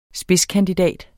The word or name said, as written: spidskandidat